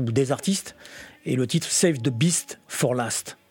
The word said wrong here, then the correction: best: C'est pas une beast, c'est best.